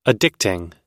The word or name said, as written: addicting